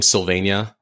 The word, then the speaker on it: Sylvania: It's at my first name, Sylvania, like Pennsylvania without the pen, and last name, Harrod.